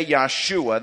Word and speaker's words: Yeshua: Now, we say Yeshua, that's the Hebrew pronunciation. The English pronunciation of it is Joshua. Joshua and Jesus are the exact same name. Jesus is simply the Greek pronunciation of the Hebrew name Joshua.